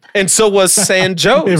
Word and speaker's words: San Jose: There was a huge Hispanic influx into that area that helped create this town, but they wanted to put it with a, they made them pronounce it with an English pronunciation. So it's San Jose.